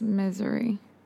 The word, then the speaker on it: Missouri: It is misery. – From St. Louis, misery.